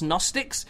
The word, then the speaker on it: Gnostics: So she at one point was talking about the Gnostics, and I'm like, I think it's pronounced Gnostics. It's like a G as in, like, the G is in, I don't know, What's another GN word? A GNU is a new, another GNU, a another Gnostic.